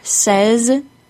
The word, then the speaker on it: says: The words says and said are pronounced with a short E sound.